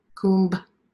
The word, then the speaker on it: Kumbh: Four places every third year, what's called the Kumbh Mela, K-U-M-B, Kumbh, you say it like that, means nectar.